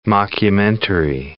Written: mockumentary

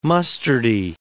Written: mustardy

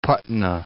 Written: Patna

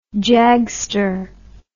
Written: jagster

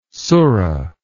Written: surra

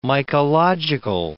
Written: mycological

